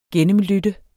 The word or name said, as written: gennemlytte